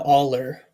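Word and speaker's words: Awl: I'm thinking of something, perhaps, building off all. – Uh-huh. – Which is, of course, a small pointed tool used to make holes. – Yeah. I think I like that, yeah. – So perhaps he's now Aller, or the Aller.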